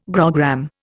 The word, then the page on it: grogram: Indian